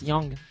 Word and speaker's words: Young: Bah, c'est Y-O-U-N-G, mais ça se prononce Yang, comme dans Ying et Yang.